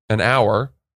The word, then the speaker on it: hour: The allotment of time, an hour, is spelled H-O-U-R.